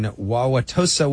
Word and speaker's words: Wauwatosa: Aileen in Wauwatosa, Wisconsin.